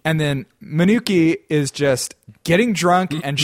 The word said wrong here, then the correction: Minako: Your pronunciation of some of these names is definitely a little racist. – Sure. How's it pronounced? – Minako, damn it. Yeah, no, that's pretty bad. Now that you say that, I realize how bad Manuki sounds.